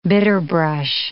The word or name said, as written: bitterbrush